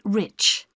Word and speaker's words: Rich: It's Mrs. Rich. – How do you spell that? – R-I-C-H.